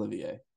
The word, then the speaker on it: Olivier: Oh, what did I say, Oliver? It's definitely Olivier. I think I'm reading.